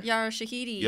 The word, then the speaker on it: Yara Shahidi: Yara Shahidi.